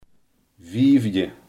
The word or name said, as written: vijfde